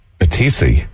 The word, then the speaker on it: Matisse: Matisse? – Matisse.